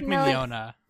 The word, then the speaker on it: Mignogna: And watch the dub, because you got Vic Milanagna in it. He plays Dark. – Wait, how do you say his name? – It's Vic Mignogna.